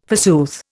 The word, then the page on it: forsooth: Indian